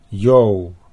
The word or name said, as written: Jo